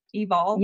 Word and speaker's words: Evol: Is that how you say it, right? Evil? Evol? – Yes, E-V-O-L, which is love backwards.